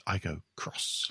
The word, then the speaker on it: Aygo X: So it's written Aygo X. It's pronounced Aygo Cross.